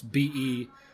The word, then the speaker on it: Belle Isle: It's BELLEISLE.